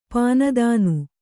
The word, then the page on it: ಪೀನೋಧ್ನಿ: pīnōdhni